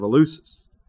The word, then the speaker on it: Eleusis: Eleusis is spelled E-L-E-U-S-I-S, Eleusis, known as the Eleusinian mystery religion, was orgiastic in character.